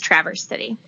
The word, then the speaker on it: Traverse City: Or do you say Traverse City? – Yep, it's Traverse City, although it's very natural to say Traverse because that's how we pronounce the word.